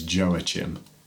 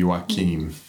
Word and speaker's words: Joaquin: Which I just point out at this point that the audiobook mispronounces his name as Joachim, but it would be Joaquin.